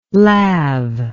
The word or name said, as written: lav